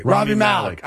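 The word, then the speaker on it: Rami Malek: In Bohemian Rhapsody, Malum Hayek, whatever his name is. Yeah. I'm mispronouncing it for sure. So he plays Freddie Mercury. – Robbie Malik. – I apologize. Thank you.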